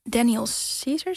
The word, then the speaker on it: Daniel Caesar: En een ander nummer wat jij had gekozen is Her Love van Common en Daniel Caesar, zeg ik het goed? – Ja.